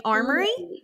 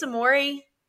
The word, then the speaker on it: Amore: I thought also in watching the broadcast, one of the announcers pronounced it Amore Lockhart and one of them was saying Armory. – Yeah. Okay. – But there is no R before the M. So I think it's Amore.